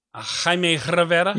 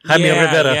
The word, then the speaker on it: Jaime Rivera: Jaime Rivera. – I got to really be able to roll the tongue. Jaime Rivera.